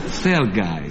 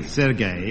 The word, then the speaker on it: Sergei: Shall we pronounce it a little bit differently? – Serge Trouserin. Sergei. – Sergei, yes, Sergei, yes.